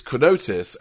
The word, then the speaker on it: Carnotus: I love the way you pronounce the word Carnotus, because it's Carnotus, and you're saying Carnitus like it's some kind of, like, chronic illness.